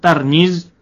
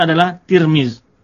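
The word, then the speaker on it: adalah Tirmiz (ترمذ): Tirmiz itu berbeda-beda cara baca, ada yang baca Tirmiz dengan baris di bawah tanya, ada yang baris di atas Tarmiz, Tarmizi, Tirmizi atau Turmuz. Tapi yang masyhur dibaca adalah Tirmiz.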